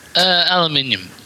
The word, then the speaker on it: aluminium: They say that differently in... – Aluminium. – Yes. – Which is how it's spelled.